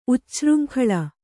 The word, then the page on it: ಉಚ್ಛೃಂಖಲ: ucchřŋkhala